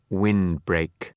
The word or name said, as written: windbreak